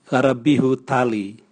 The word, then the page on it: garapihu tali: Raga